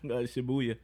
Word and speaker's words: Shibuya: Shibuya. – Shibuya. Yeah. Say that again. – Shibuya. – Shibuhu. Shibuya. Shibuya. Is that how you pronounce it, though? Shibuya? – Yeah.